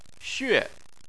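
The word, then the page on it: xue: tone 4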